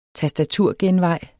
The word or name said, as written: tastaturgenvej